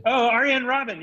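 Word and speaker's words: Arjen Robben: Ryan Robin. – You trying to talk Dutch? – He's a soccer player for the Netherlands. – Arjen Robin.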